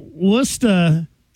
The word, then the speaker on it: Worcester: It's Worcester. – New England's number one for new country, 97.5 WOKQ. What's the most New England word that outsiders always pronounce wrong? And that one was at the top of the list. – Plus Gloucester. – Well, Worcester, is it A lot of people say the woo. That's the nickname. – But it says in parentheses right here, it says Wista. W-I-S-T-A-H.